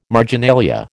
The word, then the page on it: marginalia: American English